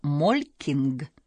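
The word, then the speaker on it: Molching: Правильнее всего произносить его как Молькинг.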